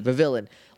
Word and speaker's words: Vivillon: Vivillon.